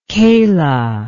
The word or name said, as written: kayla